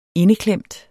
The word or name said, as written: indeklemt